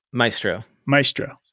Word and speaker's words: Maestro: How do you say this word? – Centage Planning Centage. Maestro. – Maestro. Yep. Got it. – Like Maestro, like, you know. – Maestro, like musician.